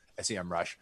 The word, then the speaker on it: SEMrush: SEMrush. And I'm just going to keep saying SEMrush to remind our listeners, it's pronounced SEM Rush, not SEMrush.